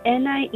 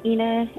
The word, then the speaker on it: Anna: Anna, Ina. It's the same. It depends if you're saying it in Spanish or in English.